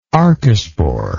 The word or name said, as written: archesporial